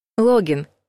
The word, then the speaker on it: логин: Логин — вход или авторизация. В написании, в общем-то, ошибаются нечасто, зато путают ударение. Согласно словарю Лопатина, логин нужно произносить с ударением только на втором слоге.